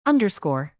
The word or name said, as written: underscore